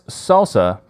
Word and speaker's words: SLSA: And this is also, so from going forward, I'm going to be pronouncing this as SALSA because that is the recognized pronunciation, which I'm a big fan of that.